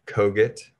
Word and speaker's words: Kogut: Easy to find on all the socials at Ben Kogut. Last name is spelled K-O-G-U-T, and happy to connect with anybody that wants to learn more.